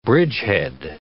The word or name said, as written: bridgehead